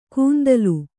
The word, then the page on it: ಕುಂತಲು: kuntalu